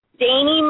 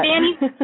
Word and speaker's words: Dani: Yes, it's Dani Marie. That's right. – All right. – Thank you. I get a lot of Dannys. I'm like, no, Dani.